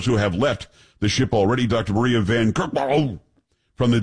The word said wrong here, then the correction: Van Kerkhove: It's Van Kerkhove. Kerkhove. I'm pretty sure that's how you pronounce it.